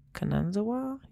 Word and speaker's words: Kanazawa: So then you also need to watch this video of them installing an exhibit in Kanazawa.